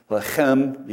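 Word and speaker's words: Lechem (לחם): It's Beth or Beit, the modern pronunciation, Lechem, you got to clear your throat, which means house of bread.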